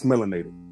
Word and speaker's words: Melanated: Did I say it right, man? Because I feel like I just butchered that. – No. It's Melanated.